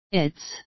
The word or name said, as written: its